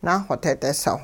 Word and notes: naaxoteedisow: hupa

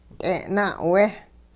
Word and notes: t'e'-na'we: hupa

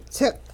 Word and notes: ts'iq: Hupa

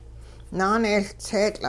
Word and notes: naanehLtseetL': Hupa

